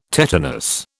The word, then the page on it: tetanus: British English